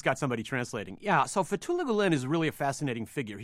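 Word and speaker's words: Fethullah Gülen: Fethullah Gulen? – Fethullah Gulen.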